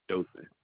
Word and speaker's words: Dothan: Dothan, Alabama. – Spell that. – D-O-T-H-A. – Oh, Dothan. – N. Yes, Dothan.